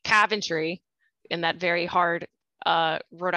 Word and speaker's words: Coventry: So please, tell me about your hometown of Coventry, Rhode Island. – Well, first of all, I have to correct your pronunciation. Oh. You're saying Coventry, which is incorrect. I am from Coventry, Rhode Island.